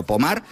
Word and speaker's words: Pommard: Si las buscáis, Pommard, se escribe Pommard, como si fuera en castellano, pero Pommard, pues allí en Francia, hablamos de unas imágenes que podrían pasar.